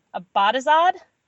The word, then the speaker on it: Abadazad: I put the emphasis on the first one. Tell me how to say it. Abadazad? – That's good. That's exactly right.